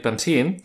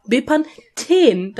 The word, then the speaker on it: Bepanthen: Bepan, und dann hast du TH. EN. Bepanthen. Da ist ein TH. Das ist ein Ten.